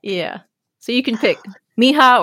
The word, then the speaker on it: Mija: So the first pronunciation is Mija. Those are her first two letters of her first and middle name. But also, so if you spell that out, M-I-J-A, it's pronounced Mija.